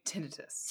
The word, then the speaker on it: tinnitus: I think tinnitus. – Tinnitus, yes. Yeah. It's spelled T-I-N-N-I-T-U-S. – Mm-hmm. – Tinnitus. Tinnitus. Anyway. – Yes. I think some people say tinnitus, but I think it's tinnitus.